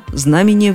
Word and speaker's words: знамение: Да, знамение времени – Гораздо чаще мы слышим совсем другое ударение знамения. Почему? Непонятно. Все словари, начиная с толкового словаря Даля, ставят ударение исключительно на «а» – знамение.